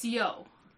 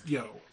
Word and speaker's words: Yeoh: It's Yeoh. – Yes, I looked it up. It is Yeoh.